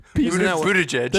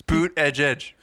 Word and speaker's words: Buttigieg: Buttigieg. – That's not it. – What is it? – It's Boot Edge Edge. Buttigieg.